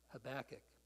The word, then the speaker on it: Habakkuk: Oh boy, somebody else pronounced his name right now. Habakkuk, yeah.